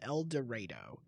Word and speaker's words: El Dorado: My brother-in-law worked in Arkansas, in rural Arkansas, for like a year in a town called El Dorado. And yes, I am pronouncing that the way it's supposed to be.